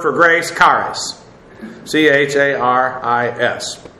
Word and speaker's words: charis: Technically, I know the term is pronounced charis, but most English speaking people, when they see this, they will pronounce the I in a short form. They won't pronounce it like a long E as it is in the Greek.